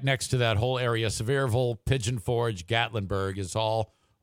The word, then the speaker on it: Sevierville: That's actually pronounced Sevierville. – Is it? – Or Sevierville, as they say there, Sevierville.